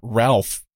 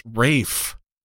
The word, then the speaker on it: Ralph: Okay, so it turns out, and just a note for like the 99% of people who won't know this, it's actually not Ralph Fiennes. It's Welsh. It's actually pronounced Rafe, which is like fucked up.